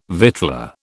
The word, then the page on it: victualler: British English